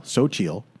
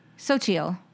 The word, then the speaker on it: Xochitl: And so it says it sounds like okay. – Xochitl, S-O-W, and then a dot, C-H-E-E-L, Xochitl. – Xochitl. – Gomez, G-O-W-M-E-Z.